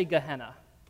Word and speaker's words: Gehenna: Let me hear you all say Gehenna.